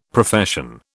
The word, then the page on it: Profession: British English